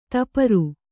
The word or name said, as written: ದಪ್ಪರಿ